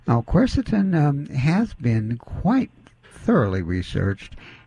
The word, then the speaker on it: quercetin: But one of their protocols, they say for people with low risk, if you can't get the hydrochloroquine, is to use, how do you say, quercetin? – Yes, quercetin.